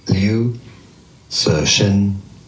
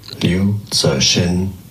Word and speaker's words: Liu Cixin: Fiction writer Liu Cixin. Liu Cixin.